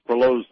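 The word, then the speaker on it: Proulx: Well, one wonders, with the success of Annie, I believe it's pronounced Perlow's Brokeback Mountain,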